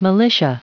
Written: militia

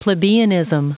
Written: plebeianism

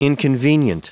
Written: inconvenient

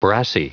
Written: brassy

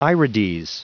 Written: irides

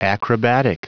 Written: acrobatic

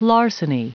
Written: larceny

